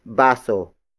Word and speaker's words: baso: It is the focus marker, indicating that the next word is the focus of this sentence, and that is baso. Baso. Baso. Baso is spelled as B-A-S-O.